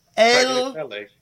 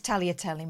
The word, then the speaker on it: Tagliatelle: T-A-G-L... – Tagliatelle. – Sorry? – Tagliatelle. – Correct. But this one it's here... Go on. – It's Tagliatelle, mate. – She reckons you don't pronounce the G in Tagliatelle.